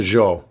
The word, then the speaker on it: Zhou: You know, the emperor or the king, you know, the of the Zhao. How do you pronounce it? The Zhou.